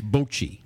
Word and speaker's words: bocce: Is it bocce or bocce? – Once again, you're pronouncing it incorrectly. – Well, you know, I only hear what I hear. – It's bocce.